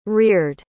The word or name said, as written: reared